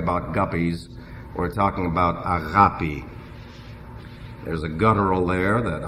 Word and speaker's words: agape: It's A-G-A-P-E, agape. Now, I pronounce it in the modern Greek, because I am a modern Greek, and therefore I will not go along with agape.